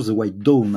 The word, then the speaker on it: The White Dawn: je précise que The White Down, c'est plutôt The White Dawn, hein, si euh, la bonne prononciation, l'aube, euh, l'aube blanche, c'est un, film de 1974.